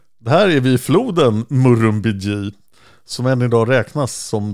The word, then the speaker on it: Murrumbidgee: Och jag fick en uttalsanvisning. Murrumbidgee, ja.